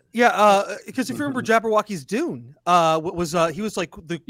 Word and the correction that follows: Jodorowsky: Wait, did you say Jabberwocky's Dune? – Am I saying that wrong? – Jodorowsky? – No, you're definitely saying that wrong.